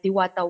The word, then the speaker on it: Diwata: Okay, so let me start by describing our development of our first microsatellite, Diwata 1, which, you got it correctly, was launched in 2016.